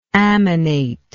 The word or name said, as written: ammonate